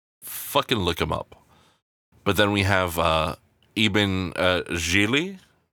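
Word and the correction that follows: Iben Hjejle: And it turns out that the correct pronunciation is Ibn Yaila.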